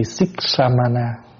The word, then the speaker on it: Siksamana: Chữ Siksamana, S-I là Sik, K-S-A-M A-N-A, Siksamana.